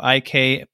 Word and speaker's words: hay: And for anyone who's just listening, it's I is spelled H-A-Y. So it's that little word H-A-Y.